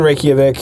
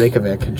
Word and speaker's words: Reykjavik: So, anywho, we land in Reykjavik. Saying that right? – Reykjavik. No, Jeremiah's got Reykjavik. You. Reykjavik. Jeremiah's got you. – Yeah, Reykjavik.